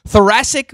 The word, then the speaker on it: thoracic: Please, excuse me if I say this incorrectly, thoracic bursitis in his shoulder. – Pretty good. I think you got it right.